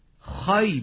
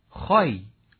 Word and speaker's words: خوی: حتما این واژه رو خوب است که خایی بخوانیم، بین خوی و خی، خایی. این تلفظ درست این واژه است به مفهوم ژاله‌ای که بر گل می نشیند، مجازن دانه های عرقی که بر گونه محبوب جلوه‌گری می کند.